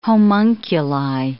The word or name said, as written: homunculi